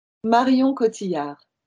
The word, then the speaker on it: Marion Cotillard: Marion Cotillard.